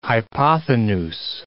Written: hypothenuse

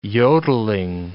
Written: yodelling

